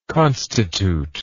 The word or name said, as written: constitutor